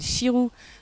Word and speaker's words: Xiru: Ah oui, Pays Basque, il y a un festival d'ailleurs qui s'appelle Chirou, il faut vraiment y aller, ça s'appelle X, alors ça s'écrit X-I-R-U, Donc chirou, ça vient de la chiroula, c'est une petite flûte d'ailleurs qui est traditionnelle de là-bas.